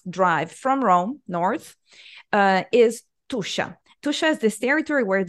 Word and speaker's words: Tuscia: You pronounced it perfectly in the introduction. Tuscia is the name of a territory roughly between Rome and Florence.